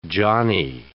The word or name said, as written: johnny